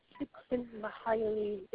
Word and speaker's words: Csikszentmihalyi: I keep trying to learn how to pronounce his name, and I can never get it right. Csikszentmihalyi. Ah, thank you very much.